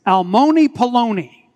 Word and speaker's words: almoni poloni: It's a rhyming phrase, almoni poloni, and rhymes with baloney.